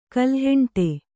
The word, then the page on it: ಕಲ್ಹೆಂಟೆ: kalheṇṭe